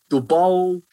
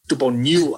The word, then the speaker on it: Tupanua: So yeah, you hit that T like it's a D again. – Tupanua. – I know certain Tongan people who have been like, that's not how you say his name. So so this is how you do say his name. Sicily Tupanua. – Sicily Tupanua.